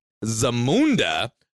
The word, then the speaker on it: Zamunda: Because she literally went to Zamunda to marry him. – That's how you say it.